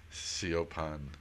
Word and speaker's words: Siobhan: And the middle girl, her name is Siobhan, S-I-O-B-H-A-N, which she spells S-C-H-E-V-O-N-E. Two N's. Because nobody can pronounce the Irish way of Siopan, you know.